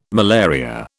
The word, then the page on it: malaria: British English